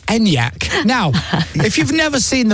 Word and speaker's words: Enyaq: It is Enyaq. I know it's Enyaq.